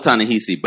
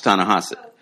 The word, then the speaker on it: Ta-Nehisi: Well, yeah, it's spelled Ta-Nehisi, but it's pronounced Ta-Nehisi.